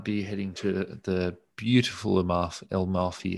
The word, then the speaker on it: Amalfi: It's 2 syllables. So, you know, Amalfi. It's 3 syllables.